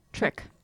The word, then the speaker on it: Trich: I never know how to say this one. Trichomoniasis? – I think that's right. – Trick? Trich? – Trick. – Trick? – Trick.